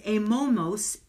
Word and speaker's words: amomos: The word holiness in the Greek, and I'm probably going to butcher how it's said, so I'll spell it, H-A-G-I-O-S, hagios, means identifiable. Blameless in the Greek was amomos, A-M-O-M-O-S, mean offering our best.